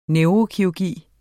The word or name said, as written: neurokirurgi